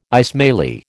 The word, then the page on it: Ismaili: American English